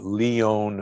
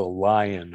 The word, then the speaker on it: Lyon: Well, as I mentioned, ALA, this short chain omega-3 fat, was the subject of a very important heart disease study called the Lyon Heart Diet, L-Y-O-N, from Lyon, France. Most people know it as the Lion Heart Diet because we don't pronounce it correctly.